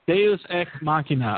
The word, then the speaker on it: Deus ex machina: If you say a due machina. – Deus ex machina.